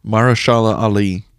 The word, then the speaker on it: Mahershala: Mahershala Ali.